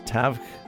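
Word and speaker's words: Tadhg: Opener will be, I have to get the pronunciation of Celtic back in my head because it's T-A-D-H-G, so I think that's Tavg Nolan.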